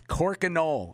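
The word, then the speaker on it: Crokinole: It's Corkinole. I'll spell it for you one more time. – Corkinole. – Cork. – Okay, I'm going to be Googling that right now. – Yeah, cork and all.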